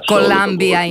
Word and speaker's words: Columbia: Columbia είναι η προφορά, η σωστή.